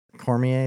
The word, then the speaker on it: Cormier: It's like Cormier. Cormier, is that how you say it? – Yeah.